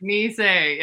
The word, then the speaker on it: Nisei: And it's Nisei Lounge, pronounced like the thing on your leg and say,